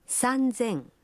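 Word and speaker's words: Санзэн (三千): Но и здесь произношение меняется. 3000 будет звучать как? – Санзэн.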